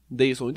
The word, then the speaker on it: Deilson: Claro que, ó, o Deilson D, Deilson... Ah, então não pode zoar que eu, ó, D-E-I-L-S-O-N, Deilson. Dayson, entendeu? Dayson. É, como é que fala?